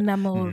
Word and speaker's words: Namor: Is that how you say it? Namor? – Namor is Namor? Fine, but you can add the... – Namor. – The Namor. Namor. Like, add a bit of the sexiness to it.